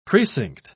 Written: precinct

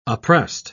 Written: oppressed

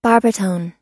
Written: barbitone

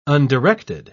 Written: undirected

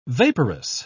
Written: vaporous

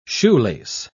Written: shoelace

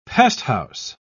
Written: pesthouse

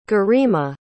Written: garima

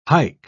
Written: hike

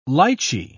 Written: lychee

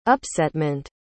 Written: upsetment

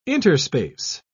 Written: interspace

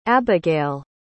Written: abigayle

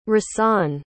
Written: rahsaan

